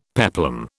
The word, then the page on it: peplum: British English